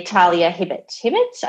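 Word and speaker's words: Talia Hibbert: By Talia Hibbett. I think I'm pronouncing that right. Yep.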